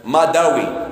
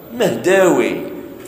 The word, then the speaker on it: مهداوي: ولهذا كاين بعض الناس واسمه مثلا مداوي، هي ماشي مداوي، مهداوي.